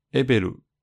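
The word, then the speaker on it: EVLE: 那这个 E V L E 呢，念作 Ebelu 啊，是爱奴语中跳舞的小熊的意思意思。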